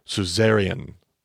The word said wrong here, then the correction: suzerain: But I found out today, in fact, that I've been pronouncing that wrong this entire time. It's actually suzerain, but we call them suzerain vassal covenants. And in the ancient world, there was two parties to a covenant, a suzerain and a vassal. – And just to clarify, that's spelled S-U-Z-E-R-A-I-N.